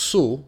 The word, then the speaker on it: Sault: This is a song from an artist named Sul, spelled S-A-U-L-T, but pronounced Soul, like S-O-O.